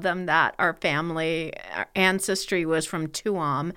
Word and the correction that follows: Tuam: Uh-oh. – And she was like, you mean Chum? – Yeah, Chum. – Chum. Okay, next one, E-O-I-N.